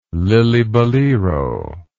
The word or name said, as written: lillibullero